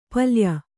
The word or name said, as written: ಪಲ್ಯ